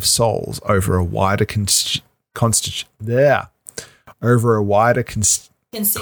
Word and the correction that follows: constituency: constituency. Constituency. Thank you. You're constituency. Putting the emphasis on the wrong syllable.